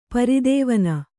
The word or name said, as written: ಪ್ರಧ್ವಾನ